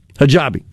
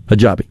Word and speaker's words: Hajibi: just appointed America's first Yemeni Sharia Hajibi, I don't know if I pronounced that correctly or not, judge. The user claimed Sharia Hajibi. – Hajibi, okay. I accented the wrong syllable there. Hajibi, thank you.